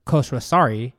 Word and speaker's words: Khosrowshahi: And according to the Uber CEO, Dara Khosrowshahi, as a company...